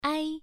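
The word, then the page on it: ai: tone 1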